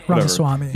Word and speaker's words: Ramaswamy: So fucking Ramaswamy, how do you say his name? – Ramaswamy.